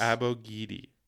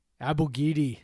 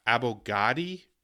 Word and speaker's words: Abogidi: So that's how you say his name. – That's how he says that he says his name. So that is how his name is said. – So that's actually it, because he said it. – And so the WSU pronunciation guide said Abogud. It's like Abogudi. And so I honestly don't know where Heister and Dickow got Abogadi. Like, I don't know where they got that.